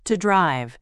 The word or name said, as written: to drive